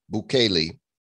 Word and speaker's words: Bukele: Is that how you pronounce his name? B-U-K-E-L-E. Bukele. Mm-hmm. Has bought the dip, and now the country of El Salvador owns 700 Bitcoin.